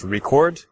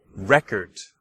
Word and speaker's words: record: So hopefully you can hear two syllables, record, and you can hear the stress on the second syllable, record. All right, but if I change the stress to the first syllable, record. Okay, record.